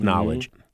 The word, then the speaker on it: Knxwledge: Now, Knowledge, and it's spelled K-N-X-W-L-E-D-G-E, but it's pronounced Knowledge.